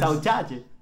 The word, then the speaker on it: saudade: llaman saudade, saudade, saudade, ¿Cómo se dice? – saudade, Sí, como que esa, o sea, ¿Sabes qué es eso?